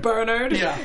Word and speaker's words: Bernard: Bernard specifically, the way that it's pronounced with an English accent, which is Bernard, is the funniest name on the planet to me.